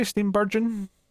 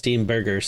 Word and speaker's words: Steenburgen: Basic premise of Dead of Winter is that Mary Steenburgen, if I'm saying her name correctly, is it Burgen, maybe? Steenburgen? – Is it soft? I thought it was Steenburger, so. – Burger? – You got meat. – No, it's definitely an N at the end.